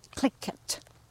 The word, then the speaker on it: Tlingit: We are here in Teslin, beside this beautiful lake, and our guest today is Doug Smarsh, and he is Tlingit. Did I say it right? – Yes, you did.